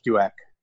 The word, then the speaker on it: Duick: Well, I mean, my last name has three vowels in a row, so it's a challenge. But it's Rich Duick.